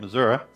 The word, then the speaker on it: Missouri: And that's how you say it, Missouri. My wife won't agree, but that's it.